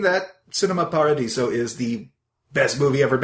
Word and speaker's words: Cinema Paradiso: Cinema Paradiso.